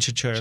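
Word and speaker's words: Chachere: So Dante Chachir, I'm gonna butcher his name, but Dante Chachir, we're saying Chachir.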